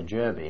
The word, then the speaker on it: Jurby: After I left Blackpool, I wasn't posted direct to Torquay, I was posted to Jerby in the Isle of Man. – Jerby? – Yes. – How do you spell that? – J-U-R-B-Y.